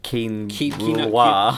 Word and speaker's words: quinoa: So, yeah, this is an advert for something called quinola. You know, goodness. – Isn't it pronounced quinoa? Quinoa. Yeah, it's literally just like Simon Brewer.